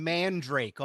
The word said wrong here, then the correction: Mandarake: all this time. – Oh, it's Mandarake.